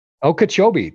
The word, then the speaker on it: Okeechobee: Her travels were limited to winter trips, and I'm not sure if I'm going to say this right, to Okeechobee. Is that right? – Okeechobee. – Thank you.